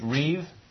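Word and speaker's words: reev (רִיב): The word for strife in Hebrew is this word that we talked about back in chapters 2 and 3, reev. Not rib, but it's pronounced as though it were spelled R-E-E-V. It's written in Hebrew, R-I-B, reev.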